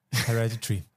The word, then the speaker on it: Hereditary: Hereditary.